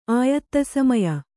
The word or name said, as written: ಆಯತ್ತಸಮಯ